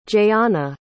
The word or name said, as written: jayana